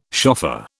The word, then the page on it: shofar: British English